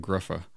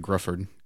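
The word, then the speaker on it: Gruffudd: His name is literally spelled G R U F F U R D, and it's pronounced like Gruffa, like Grufford, Gruffa, Gruffa. It's like Johan Griffith or something is how he says it.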